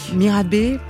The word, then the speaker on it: Mirabai: Alors j'ai dit Mirabai, mais peut-être que c'est Mirabai. – Mirabé. – Mirabé. – On prononcer Mirabé, Qui est-elle Mirabe était une princesse euh, du XVIe siècle qui était une chanteuse en fait, une artiste, euh, une chanteuse, danseuse.